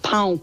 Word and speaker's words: pão: The word for bread is, it's P-A-U, but it's pronounced pão. But you have to say it really correctly. Otherwise, it's a really rude word.